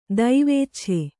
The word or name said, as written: ದೈವೇಚ್ಛೆ